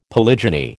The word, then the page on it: polygyny: American English